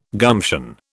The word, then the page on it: gumption: British English